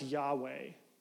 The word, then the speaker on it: Yahweh: In Hebrew, it's often pronounced Yahweh.